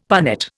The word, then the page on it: punnet: Indian